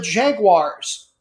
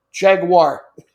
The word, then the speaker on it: Jaguar: And never pronounce it with an I. There's no I sound anywhere on it. Doesn't rhyme with Maguire, new bites. Be better than that. Jaguar. In any case, or Jaguar, if you really want to go British with it.